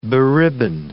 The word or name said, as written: beribboned